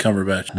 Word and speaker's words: Cumberbatch: Benedict Cumberbatch.